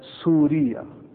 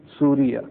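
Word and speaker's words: سوريا: In the Arabic language, Syria is pronounced as Surya. Surya.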